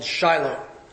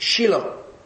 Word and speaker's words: Shiloh: Now, I'm pronouncing it the way an American would. If you were in Israel, you wouldn't pronounce it that way. You'd pronounce it Shiloh. That's how it's pronounced in Hebrew.